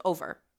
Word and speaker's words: o'er: O-E-R with the apostrophe, it's just over. He just took the V out so that he had 10 syllables instead of 11.